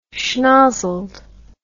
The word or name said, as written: schnozzled